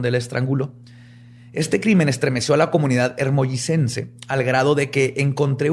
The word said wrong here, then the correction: hermosillense: Hermosillense, perdón. – No, es que me quedé trabado ahí. hermollicense. – Es hermosillense.